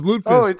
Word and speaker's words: lutefisk: Is it lutefisk? I might pronounce it. – Pretty close. Lutefisk. Obviously, lutefisk.